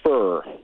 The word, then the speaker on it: Furrer: His name is Rick Furr, F-U-R-R-E-R. That's pronounced Fur.